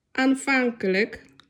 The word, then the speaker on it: Aanvankelijk: Pamiętajmy, że L i J K na końcu wyrazu wymawiamy jako lyk. I takie słówka mamy. Frolik. – Frolik. – Aanvankelijk.